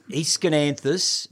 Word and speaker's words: Aeschynanthus: Aeschynanthus. – Which is spelt? – Aeschynanthus.